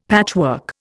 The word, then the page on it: patchwork: Indian